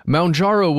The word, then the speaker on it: Mounjaro: It says Mound, like as in mountain, Mounjaro. But okay, this is their press release. – Yeah. – Quote, Mounjaro